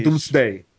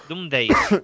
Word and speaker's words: Doomsday: Doomsday. Madrid Days, sí. – Pero es que son muchos. ¿Cómo se dice? ¿Doomdayses? – Doomsday.